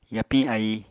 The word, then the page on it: yapiñ ái: Apatani